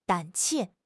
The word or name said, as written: 胆怯